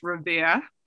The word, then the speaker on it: Revere: So I live in Revere, Mass, about 5 minutes north of Boston, pronounced Revere. No R's, got our Boston accent.